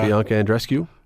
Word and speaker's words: Bianca Andreescu: Bianca Andreescu? – Yep.